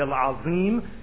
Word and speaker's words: Al-Azim: And I'd like to make a special note, we should try our best to pronounce these names correctly and not say Subhana Rabbi Al-Azim, because Azim, you're saying glory to my Lord, the wretched. It's Al-Azim.